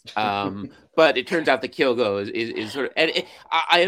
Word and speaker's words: Kyogo: So it's just Kyogo, and it's just Kyogo, and it's just two syllables, Kyogo.